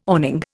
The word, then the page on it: awning: Indian